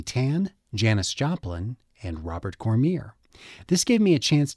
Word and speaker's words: Cormier: It was shortly after my chat with Juanita that I discovered that the name of one of the authors we discussed was correctly pronounced Robert Cormier. That's according to his family in Vermont.